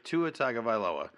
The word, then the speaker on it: Tua Tagovailoa: If Tua Tagovailoa, and that is how you say his name, yeah.